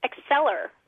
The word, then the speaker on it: Accelir: Acceler, like accelerate.